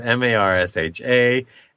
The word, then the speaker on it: Marcia: Marcia as Marsha.